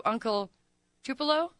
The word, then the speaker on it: Uncle Tupelo: But February 1st at Off-Broadway for the No Depression, a tribute to Uncle Tupelo. Am I pronouncing that correctly? – Yes.